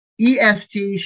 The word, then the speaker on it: est: EST, short EST, est means he, she, or it is. Long EST, pronounced aced, means he, she, or it eats.